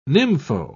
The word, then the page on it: nympho: British English